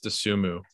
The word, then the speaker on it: Dosunmu: I've heard it's pronounced Dosunmu, but okay, Dosunmu.